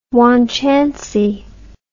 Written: wanchancy

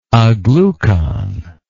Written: aglucon

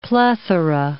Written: plethora

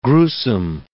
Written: gruesome

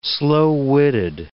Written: slow-witted

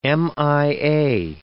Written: MIA

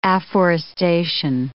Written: afforestation

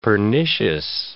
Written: pernicious

